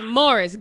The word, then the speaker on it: Morris: The game is in Cleveland, by the way, and you just lost Maurice Claiborne. So who's going to guard Corey Coleman? – It's Morris. Get his name right.